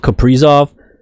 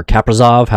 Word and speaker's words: Kaprizov: Central Division, you got for Minnesota, Kirill Kaprizov, or Kaprizov, however you want to pronounce it, but it's Kaprizov, I believe.